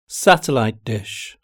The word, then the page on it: satellite dish: British English